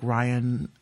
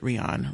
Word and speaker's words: Rion: I really like Ryan, she pronounces it Rion.